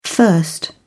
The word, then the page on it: thirst: British English